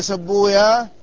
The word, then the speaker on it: سيبويه: يقولون ان المحدثين لا ينطقون بويه، ذلك ان ويه من اسماء الشياطين، وبين المحدثين وبين الشياطين عداوة، ولذلك كل اسم جاء مختوما بهكذا بويه هم ينطقونه، فيقولون في سيبويه سبويا، وفي راهويه راهويا، وفي نفطويه نفطويا، وهكذا.